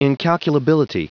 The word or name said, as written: incalculability